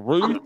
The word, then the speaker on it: Routh: How do you say your last name? Is it Roth or Ruth or what is it, Luke? – Ruth. – I completely fucked that up. Alex asked me how to pronounce it, and I said Roth, and I was completely wrong. Hey, Alex's last name is Ruth. His last name is Ruth, not Roth, Alex.